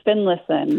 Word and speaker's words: Finlayson: I'm from Finlayson, Minnesota.